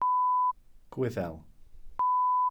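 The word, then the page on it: Gwyddel: Welsh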